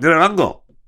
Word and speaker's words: Nyiragongo: I'm pretty sure that's how they pronounce it. Mount Nyiragongo, Nyiragongo.